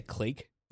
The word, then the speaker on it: clique: Say clique. – Clique. – That's how it's actually pronounced. – Clique. Yeah. – I mean, everyone says clique like unless they're really pretentious, like Swagger, but it's technically pronounced clique.